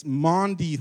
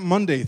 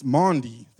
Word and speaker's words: Maundy: But it's Maundy Thursday, right? It's not Monday Thursday. It's Maundy Thursday.